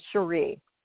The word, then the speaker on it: Sheree: And when you've been watching Lana for all these years, and you have I still pronounce her name wrong. It's Sheree. I even wrote it out phonetically. It's Garcelle and Sheree. So, yeah, as I look at it and I think Sheree, I'm thinking she by Sheree for Atlanta, but no, it's Sheree.